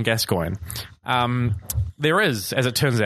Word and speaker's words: Gascoigne: I learned his name because they pronounce it incorrectly as Gascoigne. I now know it's Gascoigne.